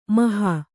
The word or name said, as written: ಮೋಹ